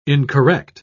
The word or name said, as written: incorrect